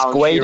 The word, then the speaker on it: Guedes: It's Guedes, I think. So it's like how I sort of phonetically put it was, it's like G'day, but G'way, Des.